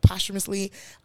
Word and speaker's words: posthumously: That's how you say it, posthumously.